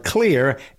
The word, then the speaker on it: Xlear: Announcing or introducing you, if you haven't heard before, about Clear, X-L-E-A-R. It's spelled that way because the main ingredient is xylitol, which is spelled with an X. So to get your attention and so you'll know what it's all about, the ingredient starts the word clear.